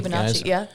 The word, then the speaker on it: Fibonacci: We were talking about the Fibonacci. Am I saying that correctly? – Yeah, Fibonacci.